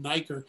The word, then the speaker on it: Nieker: You have to, you can just Google my name, David Nieker.